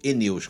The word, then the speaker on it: aeneus: Geralmente, coridora albina é da espécie Coridoras aeneus, Eneus que fala. Eu falei, eu falei Aeneus, mas é Eneus que pronuncia.